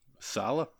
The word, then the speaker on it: Saleh: Saleh? – Yes.